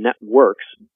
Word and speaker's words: Netwrix: I'm going to guess pronunciation, Networks, but it's NETWRIX.